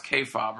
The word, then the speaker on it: kayfabe: it's pronounced kayfabe, right? – Kayfabe.